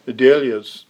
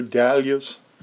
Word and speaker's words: dahlias: I was in conversation yesterday with a lady who had some preeminence in the Scranton Horticultural Society, and we were talking about growing dahlias, or I think as you pronounce them here, dahlias in the States.